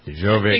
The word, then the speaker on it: Djokovic: Serbian tennis superstar Novak Djokovic. Is that how you pronounce it? – Sure. – Djokovic. – Djokovic, I don't know. – Djokovic.